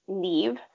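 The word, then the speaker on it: Nive: And it's N-I-V-E, but it's pronounced Neve.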